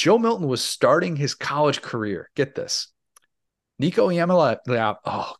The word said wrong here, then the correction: Nico Iamaleava: Nico Iamaleava he was still in eighth grade at time when Joe Milton is starting his college career.